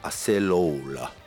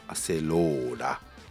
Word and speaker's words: アセローラ: 日本ではアセロラの、呼称が一般的となってますが、世界的にはアセローラ、アセローラ、アセローラ、アセローラって、ね、ローにアクセントつけるんだって、